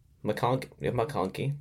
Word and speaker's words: McConkey: McConkey, that's how you say it.